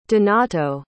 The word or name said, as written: donato